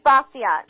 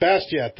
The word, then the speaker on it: Bastiat: It's Frederick Bastiat. – Bastiat, thank you very much.